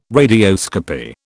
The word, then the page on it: radioscopy: British English